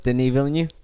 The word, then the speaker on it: Denis Villeneuve: Pronounce the director's name. – Denis Villeneuve. – Okay. – Denis Villeneuve. He just heard that and was like, that is a perfect pronunciation of my name.